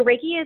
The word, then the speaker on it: Reiki: Yeah, so Reiki is another energy technique.